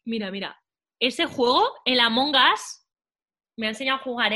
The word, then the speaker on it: Among Us: Among Us.